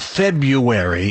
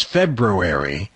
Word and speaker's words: February: Yeah, there's that R in there, that second R. – Yeah, it's not February, it's February. Only professionals like ourselves really know how to pronounce it.